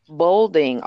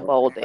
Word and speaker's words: Bolding: Bolding. Artisha Bolding.